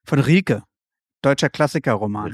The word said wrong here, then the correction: Rilke: Rilke gleich liest er.